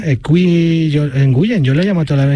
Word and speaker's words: Nguyen: Muchos jugadores americanos vietnamitas tienen esa, pero se pronuncia Win. Se pronuncia W-I-N, se pronuncia Win.